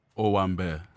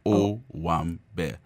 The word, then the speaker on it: Owambe: Taste of Owambe? – Owambe. – Owambe? – Yeah, Owambe. Think of it as like three syllables. Owambe.